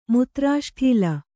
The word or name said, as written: ಮುತ್ರಾಷ್ಠೀಲ